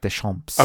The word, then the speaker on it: Deschamps: Lovely French pronunciation there, Boom Boom, of Deschamps.